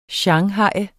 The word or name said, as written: shanghaje